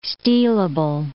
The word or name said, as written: stealable